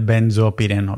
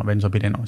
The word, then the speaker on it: benzopireno: El ben, benzo, siempre lo digo mal, tío, el benzopireno, benzopireno, sí, benzopireno, benzopireno.